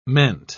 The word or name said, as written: meant